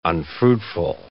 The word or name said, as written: unfruitful